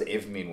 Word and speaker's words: Avomeen: And so it was that I it was that because Avomeen won an award where we like won. was, what was it? How do you pronounce it? Avomeen.